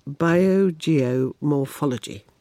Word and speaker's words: biogeomorphology: First of all, what is, and I will say it again, biogeomorphology? – Excellent pronunciation there.